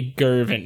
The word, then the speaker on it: Girvin: There are claims that one of the Bean's daughters eventually left the clan and settled in Jervin. It might be Girvin. I don't know. It's a G. I don't know if it's a hard G or a soft G.